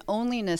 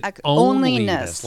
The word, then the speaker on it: Onlyness: is called an onlyness statement. So we go through this, and it's a branding. – You said onlyness. Like O-N-L-Y. – Right. – Onlyness. – O-N-L. – Okay. – Yep. Rhymes with loneliness.